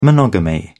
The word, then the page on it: monogamy: British English